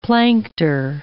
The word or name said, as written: plankter